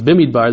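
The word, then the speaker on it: Bemidbar: And in the beginning of Bamidbar, even before the psukim that we read, of course, the beginning of Bamidbar is, more correctly pronounced, Bimidbar.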